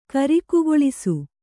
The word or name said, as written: ಕರಿಕುಗೊಳಿಸು